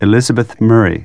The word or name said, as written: Murray